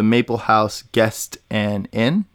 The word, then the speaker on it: Maple House Guest and Inn: If you guys are listening, check out the Maple House Guest and Inn. Did I say it right? – Yep, you did.